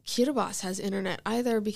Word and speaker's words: Kiribati: There's this little country, it's pronounced Kiribati, but it's like spelled Kiribati,